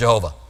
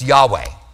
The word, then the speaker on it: Yahweh: And Hebrew scholars are pretty confident that it's pronounced Yahweh. They're not 100%, but they're pretty confident. There is no J sound in Hebrew, by the way, which is how we know it wasn't Jehovah.